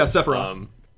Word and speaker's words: Sephiroth: Yeah, Sephiroth.